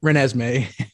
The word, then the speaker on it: Renesmee: How do you pronounce it? Renesmee? – Renesmee.